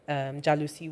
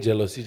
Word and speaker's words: jalousie: Thanks for the proper pronunciation, by the way. Everyone has been saying jealousy. – Mm. Jalousie. – But it's like a French jalousie.